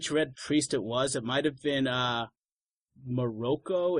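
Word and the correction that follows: Moqorro: if that's how you say it. The big one that's a... – Yeah, I think it's... Isn't it Makoro? – Makoro, thank you. Yes, Makoro.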